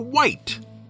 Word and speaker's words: wight: Now, the White Box is called the White Box because it's the color of the box, W-H-I-T-E. But the monster's name, which is phonetically identical, is spelled W-I-G-H-T.